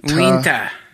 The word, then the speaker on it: Uintah: U-I-N-T-A-H. – Unta. – Unta. – Winta. – Winta. Winta County. – Winta County. – Winta? Is that what it is? How do you say it? – Winta.